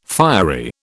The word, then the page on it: fiery: British English